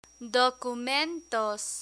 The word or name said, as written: documentos